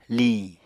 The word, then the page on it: liñ: Apatani